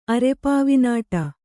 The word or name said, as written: ಅರೆಪಾವಿನಾಟ